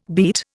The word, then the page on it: beet: Indian